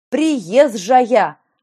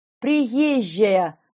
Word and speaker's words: приезжая: Приезжая! А надо как?» «Как?» – недоумевающе спросила я. «Приезжая! Понимаете? Мягко, смягчая сочетание «з» и «ж». Это должен быть единый мягкий «ж».